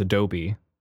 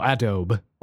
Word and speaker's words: Adobe: Like I thought, I for the longest time didn't know how to pronounce Adobe. – Okay, it was like Adob. Yeah, I didn't know Adobe or Abode.